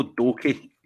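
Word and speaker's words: Doekhi: Was it Doker? Did you say his name? – No, Danilo Doke. – Doke.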